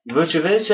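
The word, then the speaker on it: Vučević: Nikola Vucevic? – Vucevic, I think, is the correct pronunciation there.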